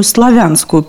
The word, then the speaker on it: Славянск: В 1794 году утвержден городом и переименован в Славянск, что подчеркивало исконную славянскую принадлежность Северного Причерноморья. Следовательно, ударение в названии «Славянск» как в прилагательном «славянский».